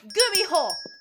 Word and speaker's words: Gumiho: By the way, it's pronounced Gumiho, idiot!